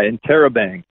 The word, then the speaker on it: Interrobang: Yeah, Interrobang.